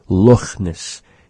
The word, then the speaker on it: Loch Ness: Loch Ness. He pronounces the CH in the word just like we do in Nechalk, the CHA sound. It's sort of a raspy sound, resembling slightly an H sound.